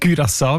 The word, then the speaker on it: Curaçao: Curaçao mit «ü» in dem Fall.